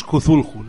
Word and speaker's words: Cthulhu: En el manual de la llamada de Chulu de Joke Internacional, pero ya la pronunciación es Cthulhu, la correcta. – Es, bueno, pues, inleíble. Yo le llamo Cthulhu.